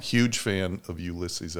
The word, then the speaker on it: Ulysses: Ulysses.